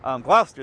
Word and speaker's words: Gloucester: and also The Bishop of Gloucester. – Y también el Obispo de Gloucester. – Gloucester, that's how you say it.